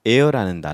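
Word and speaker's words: heir: 그러면 이때 adhere가 있는데 이 adhere에서 나온 단어 중에 here의 미치국고 here와 같은 단어가 air라는 단어가 있습니다. air는 h-e-i-r로 써야 되는데 이때 h는 묵음으로 읽을 때는 air라고 읽어야 합니다.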